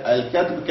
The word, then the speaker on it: الكتب: ولا يقع الطلاق بالكتب، قال بفتح الكاف، مصدر كتب كالكتابة، يعني الكتب كالكتابة.